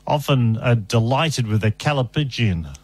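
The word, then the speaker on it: Callipygian: I don't know, but it is pronounced Calla, as in Calla. – C-A-L-L-I. Mm. Calli. Mm. Pigeon. – Calipigian. – P-Y-G-I-A-N. Calipigian.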